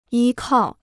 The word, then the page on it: 依靠: yī kào